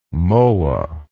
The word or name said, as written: mohwa